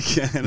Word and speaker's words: fleek: And then he's there, and then they're like, he's Fleek. Fleck. – Fleek. It's Fleek. – He's not Fleek. – Again, like, it's usually more for women. So, like, you really are not listening. You not only got the pronunciation entirely wrong, it's not even a hard word.